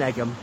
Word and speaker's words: Egham: So don't pronounce the H in Egham, and it's a single G in Egham. I know she thought it was egg, E-double-G, just like an egg that one eats. No, it's not.